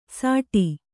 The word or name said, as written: ಸಾಟಿ